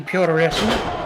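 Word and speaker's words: puroresu: Yes, puro resto, I believe is how you say it. I know my Chinese. Oh, you said Japanese. I know Chinese too. – It's actually puro resto.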